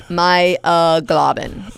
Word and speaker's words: myoglobin: Are you sure you pronounced that right? – It's myoglobin. Myoglobin.